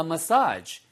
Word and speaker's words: massage: 발음 주의하세요, 이거. 메시지 그러면은 전달하는 메시지고요. 예, 그 다음에 전달 사항인 거죠. 얘는 massage예요. massage. 발음은 두 가지입니다. 메사지 하셔도 되고 머사지 하셔도 돼요. 그래서 저는 머사지를 더 선호하고요.